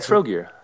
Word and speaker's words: Trogir: Trogir.